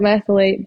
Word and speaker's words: methylate: And so that means we don't methylate B vitamins. Am I saying that right? – Yep. You don't methylate B vitamins.